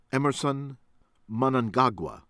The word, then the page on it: Emmerson Mnangagwa: American English